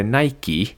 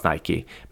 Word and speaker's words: Nike: Eh, det är klart att det skulle uttalas Nike, men, men det var inte naturligt utifrån själva stavningen.